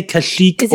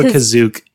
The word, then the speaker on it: Kashyyyk: You can say Kashyyyk or Kazook if you're referring to the planet that the Wookiees come from.